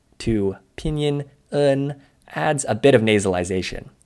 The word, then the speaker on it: en: You can add an N to the end of the sound to get un. Be careful not to pronounce en the English way, like n. – Or the Spanish way, en, because pinyin en is a unique sound, un.